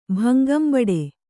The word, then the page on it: ಭಂಗಂಬಡು: Kannada